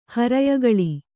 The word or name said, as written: ಹರಯಗಳಿ